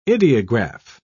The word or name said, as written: ideograph